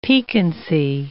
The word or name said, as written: piquancy